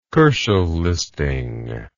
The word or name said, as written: Kochel listing